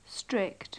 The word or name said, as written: strict